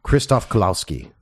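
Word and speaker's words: Krzysztof Kieślowski: But the Polish director here, Please pronounce his name. – No, my God. – Krzysztof Kulawski. – Is that how it's actually pronounced? – Yeah.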